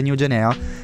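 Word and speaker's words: Nu Genea: Sì, sì, sì, abbiamo scoperto che la pronuncia corretta è New Genea, e quindi il mio istinto era...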